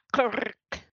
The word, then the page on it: K'rrik: Phyrexian